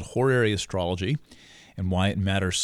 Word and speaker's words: horary: That's pronounced horary, by the way, H-O-R-A-R-Y.